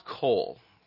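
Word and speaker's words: qowl: It's the word, it's pronounced Kol. It's Q-O-W-L.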